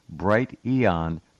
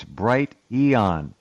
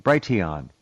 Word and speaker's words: Brighteon: You're also the first person I've ever heard pronounce Bright Eon properly. Even Mike Adams doesn't say it right. It's Bright Eon. If they had capitalized the E, they'd have had it. He says Brighteon, Brighteon, Brighteon.